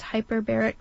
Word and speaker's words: hyperbaric: She's over there for 7 weeks doing this hyperbaric chambers. Am I saying that right? – You're saying it right.